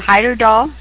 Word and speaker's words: Heyerdahl: I will pronounce his name, Heyerdahl,